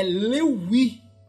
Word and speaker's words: Lévi: Levi com, com um V pronunciado como se fosse U. Por isso que o Louis virou Levi.